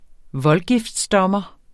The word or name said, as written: voldgiftsdommer